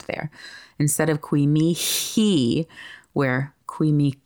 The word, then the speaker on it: mihi: Now, according to Bruce Sullivan's Layman Primer on Liturgical Latin, or at least according to my friend Lori's summary of that book, the letter H would be silent when sung. However, stylistically, at our parish, we tend to pronounce the H as though it were like a letter K, Mihi.